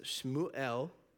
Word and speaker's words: Shmuel: The Hebrew word for Samuel is actually pronounced Shmuel, which it's from the verb shema, which means to hear, and from the noun Elohim, which means God.